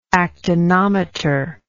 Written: actinometrical

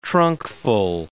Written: trunkful

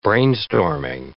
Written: brainstorming

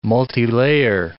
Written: multilayer

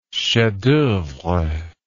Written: chef d'oeuvre

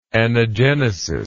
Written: anagenetical

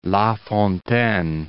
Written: LaFontaine

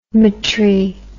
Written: metry

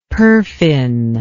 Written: perfin

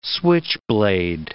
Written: switchblade

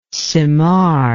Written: cymar

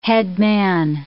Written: headman

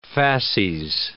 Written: fasces

